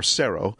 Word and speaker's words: Sero: Well, the latest addition to Samsung's TV range is I guess it's pronounced Cero, S-E-R-O. Or Cero.